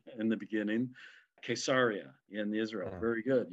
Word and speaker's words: Caesarea: Remember the Israeli pronunciation, because it's Caesarea. – Very good. Caesarea. Yeah, so it's a hard K.